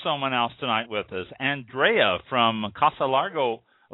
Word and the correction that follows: Larga: Larga, I believe it's pronounced, Casa Larga Vineyards.